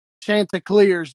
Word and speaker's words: Chanticleers: So that's the rundown on the Chanticleers.